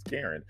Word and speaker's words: Karan: So it was like, it's spelled Karan, but her name is Karen.